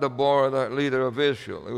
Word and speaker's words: Deborah: Deborah, or Deborah, some people pronounce it, is herself called a Mother of Israel.